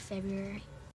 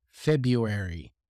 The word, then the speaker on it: February: So you can clearly hear that a lot of people would never pronounce the R, and they would say February. I actually say it myself, February. I never pronounce the R after the B.